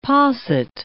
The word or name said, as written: posset